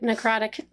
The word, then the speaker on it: necrotic: Yep, necrotic.